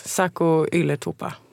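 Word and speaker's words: Saku Ylätupa: 2 norrmän och en liten finne som kom från Ajax, Sako Ylätupa. Jag tror att jag uttalade det rätt. – Malin, du är halvfinne, var det rätt? – Ja, det var nära i alla fall.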